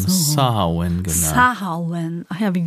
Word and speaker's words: Samhain: Also es wird Samhain geschrieben, komischerweise. – Ja, also wenn das irisch ist, dann ist ja die Aussprache und die Schreibweise immer recht unterschiedlich.